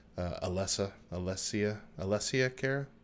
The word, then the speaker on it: Alessia Cara: It's also got "Me" by Taylor Swift, "Scars to Your Beautiful" by Alyssa Cara, Alessia Cara.